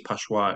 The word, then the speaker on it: Pascua: Jerome Pascua. – Oh, yeah, he's a legend, eh? – Is that what, I think it's Pashua, actually. I'm trying to say it right.